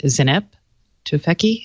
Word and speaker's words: Zeynep Tufekci: But it was based off of, I can never say, Zeynep Tufekci? Is that how you say her name? – Yeah. – Zeynep, yeah.